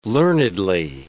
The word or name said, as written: learnedly